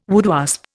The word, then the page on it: woodwasp: Indian